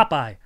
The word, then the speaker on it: Popeye: Oh, it's a popped eye. Yeah. Popeye.